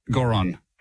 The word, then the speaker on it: Goron: Goron, G-O-R-O-N. – G-O-R-N. – G-O-R-O-N. – G-O-R-O-N, okay, cool.